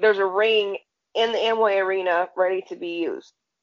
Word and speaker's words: Amway: Amway, thank you.